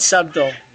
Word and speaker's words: subtle: You meant did you subtle. Just say subtle? – No, I meant subtle. – Very good. – I said subtle. – You must. – The B is not pronounced.